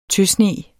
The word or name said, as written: tøsne